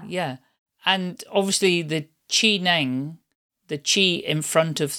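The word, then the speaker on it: Zhineng: Yeah, Qineng Qigong, which is spelled as C-H-I or Z-H-I. It's spelled in two different ways. And Neng is N-E-N-G.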